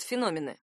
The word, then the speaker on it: феномен: Какие же бывают феномены? Во-первых, так называют необычное, особенное явление, какой-то редкий факт. Это феномен, именно с ударением на «о».